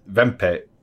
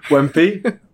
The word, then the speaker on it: Wempe: Wempe? – Let's go Wempe because I find it more amusing when you say Wempe. – Vempe. – Vempe. – You're going to say it like a German.